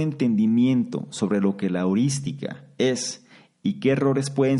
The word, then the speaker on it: heurística: heurística. Heurística, con H y con acento en la primera I.